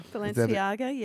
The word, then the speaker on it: Balenciaga: Well, am I saying this right? Balenciaga? – Balenciaga, yes.